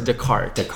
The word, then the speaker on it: Descartes: Descartes, right? – Oh, don't say it like that. It's Descartes.